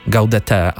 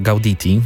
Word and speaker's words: Gaudete: Natomiast fascynujące jest to, że piosenka, której posłuchamy, czyli albo, zależy jak chcecie wymawiać, Gaudete albo Gauditi, zależy czy z angielskiego, czy oryginalnie z łaciny, tak jak się powinno, no ten kawałek odnosi się dość luźno do jednej z tradycyjnych pieśni świątecznych.